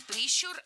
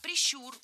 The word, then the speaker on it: прищур: Не прищур, а прищур. Ударение в этом слове ставим на второй слог. Да там его и оставляем во всех формах. Только прищур, прищура, прищуре.